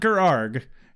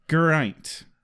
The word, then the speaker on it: Geraint: But he did say it casually a couple of times, and I suppose I was supposed to contextually pick up that it's pronounced Gerint. Gerint. Yeah, not Gerarg, Gerint. So I was, so it's a hard G, and instead of ain't, it's ain't, which is which is very yeah, British.